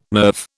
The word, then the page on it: nerve: British English